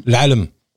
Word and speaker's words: L'Alam: Euh, donc c'est euh, comment on prononce L'Halem L'Alam. L'Alam.